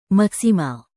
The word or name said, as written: maksimal